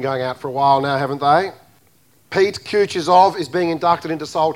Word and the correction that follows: Kutuzov: Tell me, brother, what is it? Kutuzov. Okay, got that?